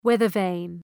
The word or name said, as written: weather-vane